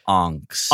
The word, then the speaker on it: Angst: So Angst is today's film. – Ryan, boy, it's pronounced Angst. – Angst. Sorry. Angst. And what as a German title too, right?